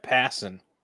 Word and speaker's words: Passan: So Jeff Passan, who like passing a basketball. That's how you say his name, in case you didn't know.